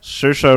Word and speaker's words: Saoirse: Saoirse Ronan.